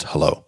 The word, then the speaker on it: Hullo: It's Hullo Pillow, H-U-L-L-O, pronounced hello.